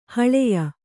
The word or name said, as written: ಹಳೆಯ